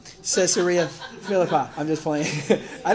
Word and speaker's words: Caesarea: It says, "Jesus went out with his disciples to the villages of Caesarea Philippi." I might have said that wrong. Caesarea, thank you.